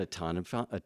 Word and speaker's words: automaton: And he said that if you got rid of likes and dislikes, would you be an automaton? How do you say that word? Automaton. And Master said, no, you would be like God.